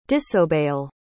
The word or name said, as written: disobeyal